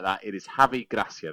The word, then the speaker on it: Gracia: His name is definitely Javi Gracia. So it's not misspelled, it's not Garcia.